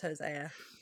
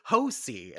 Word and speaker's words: Hosea: I feel like I can hear Arthur saying it in the game as Hosea. – I've completely forgot how he pronounced it. And then my brain, when I looked at it, went to Hosi. And I was like, nope, that that can't be right.